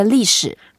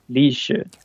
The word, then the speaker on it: 历史: We say 历史。 – 历史。 – 历史。 – 历史。 – Yeah, Li is the first word, it's the fourth tone, and that means going through.